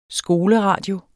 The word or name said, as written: skoleradio